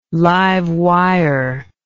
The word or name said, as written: live wire